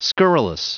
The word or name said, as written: scurrilous